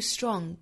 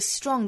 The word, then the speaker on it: strong: 后面这个 strong 的发音，我们要注意的一点就是这个 tr 的辅音连缀，在这里呢发成类似于 dr 的发音，不送气了，不是 tr。不是 strong, 而是读成 strong,